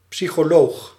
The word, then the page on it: Psychologin: IPA: /psyçoˈloːɡɪn/; German